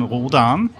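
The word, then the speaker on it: Rhodan: Ich kann mich erinnern, ich habe mir ein Hörspiel angehört und da war so die Diskussion oder irgendein Interview, das heißt nicht Roden, sondern Rodan.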